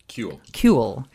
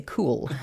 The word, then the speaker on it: CUEL: Kaibel is interested in Alameda Point in his capacity as co-director of the Law School Center on Urban Environmental Law, and we pronounce that CUEL. – I keep wanting to say cool.